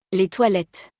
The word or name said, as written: Les toilettes